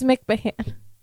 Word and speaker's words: McMahon: That's how you say McMahon? – McMahon, she said. – It says H-O-N. – It's McMahon. Or whatever his name is. – What is McMahon. – Well, why would it not be M-C-M-A-N? Stupid name.